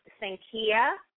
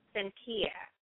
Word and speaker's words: sankhya: The Hindi word is sankhya. Sankhya. It is spelled S as in Sam, A N K H Y A. Sankhya.